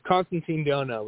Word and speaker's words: Constantine Dhonau: Am I pronouncing your name correctly? – Oh, you're pretty close. You know, it's always a guessing game with people. It's someone I like to play. It's Constantine Dhonau, but that's all right.